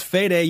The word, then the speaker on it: Fede Alvarez: Just kidding. I know his name is pronounced Fede.